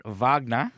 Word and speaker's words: Wagner: As I said, I thought, as the Germans pronounce it, Wagner.